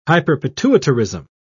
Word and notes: hyperpituitarism: British English

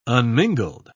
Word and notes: unmingled: British English

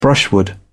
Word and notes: brushwood: British English

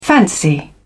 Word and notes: fancy: British English

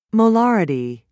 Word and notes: molarity: British English

